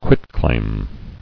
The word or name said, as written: Quitclaimed